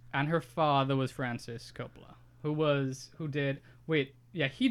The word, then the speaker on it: Coppola: He's the nephew of Francis Ford Coppola.